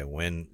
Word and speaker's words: Winn: How do you spell it? – W-I-N-N. – Okay, Wynn, Michigan.